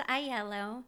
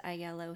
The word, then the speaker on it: Aiello: So it's pronounced Aiello. – Okay. – It is a Sicilian last name. And when my grandparents came over here from Sicily, sometimes people would pronounce it Aiello before that, but it's pronounced Aiello here in the United States.